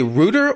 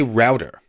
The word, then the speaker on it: router: So I Googled this because I know you pronounce it router, and I've heard other people who live in the UK pronounce it router, and it seems like that's a common UK pronunciation.